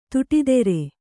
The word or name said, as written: ತೋಟದೂರ